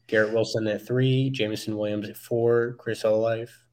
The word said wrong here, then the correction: Olave: I, I know I said that wrong. – Olave, yeah, he hates, yeah, he hates Ohio State fan.